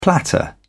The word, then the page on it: platter: British English